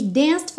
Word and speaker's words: danced: Sapphire Moonlight We danced for hours in the sand. Atenção aqui que o é do danced não é pronunciado, então nem tente falar danced for hours, ok? Danced for, danced for.